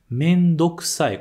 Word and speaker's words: めんどくさい: Só que quando a gente junta a palavra mendo mais ksai, quer dizer que está com preguiça, mas a gente junta e esse u acaba comprimindo, então a gente acaba não pronunciando muito esse u. Então a gente não fala muito mendo ksai, mas sim mendo ksai, como se fosse simples, sem o prolongamento, beleza?